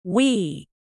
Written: wee

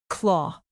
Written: claw

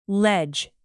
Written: ledge

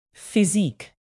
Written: physique

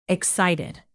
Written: excited

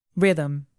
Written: rhythm

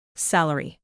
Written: salary

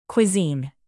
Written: cuisine

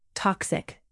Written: toxic

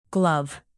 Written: glove

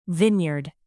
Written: vinyard

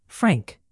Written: frank

